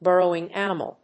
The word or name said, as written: burrowing animal